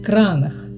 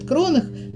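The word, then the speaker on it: Kronach: Он взял потом название города, которое тогда произносилось как Кранах. Сегодня немцы произносят Кронах, название этого города. Вот, а тогда он произносился как Кранах.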